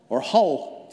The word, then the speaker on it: Hull: I'll give you a clue, he was MP, the locals don't pronounce the first letter of the name. He was MP for Hull, to you and I.